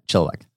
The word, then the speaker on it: Chilliwack: He is a realtor at Century 21 Creekside in Chilliwack. Did I get that right? – Yeah, Chilliwack.